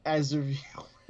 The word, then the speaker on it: Aziraphale: Aziraphale.